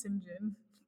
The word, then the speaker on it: St. John: Oh, well, like, there's a guy named St. John, but it's pronounced St. John.